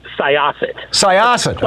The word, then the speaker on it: Syosset: Soyoset, New York. Am I saying that right? – Close. Syosset.